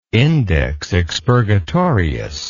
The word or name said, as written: index expurgatorius